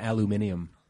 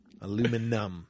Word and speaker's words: aluminum: We wrap them in aluminium. – Mm-hmm. Aluminium. I was like, that's not even close to right. That just doesn't... Aluminum. That's how it's spelled.